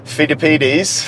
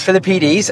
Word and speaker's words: Philippides: Phil, Phil, Phil, Philippides. – Philippides, a Spartan messenger, allegedly took this route to pass on the message that the Greeks had won the Battle of Marathon.